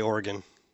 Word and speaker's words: Oregon: Is it Oregon or Oregon? – I say Oregon. – Okay. – Although when you say Oregon Trail, it feels like you say Oregon Trail, but when I say I think it's Oregon, too.